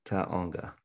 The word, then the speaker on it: Taonga: Three syllables, as you've got that down. First one's ah, second one's oh. – Okay, Taonga, Leslie.